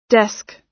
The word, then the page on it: desk: American English